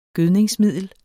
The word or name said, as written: gødningsmiddel